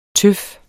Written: tøf

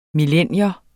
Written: millennium

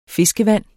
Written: FISKEVAND

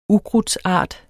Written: ukrudtsart

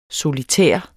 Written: solitær

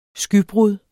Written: skybrud

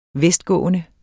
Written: vestgående

